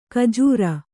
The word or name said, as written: ಕಜ್ಜೂರ